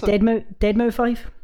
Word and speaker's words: Deadmau5: Or Deadmau5. I'm not really... You know, I'm still not entirely sure how to pronounce it. – It's Deadmau5.